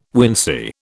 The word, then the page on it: wincey: British English